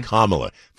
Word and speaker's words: Kamala: Kamala. – Kamala. – Think of like Pamela, but then change it to A. Kamala.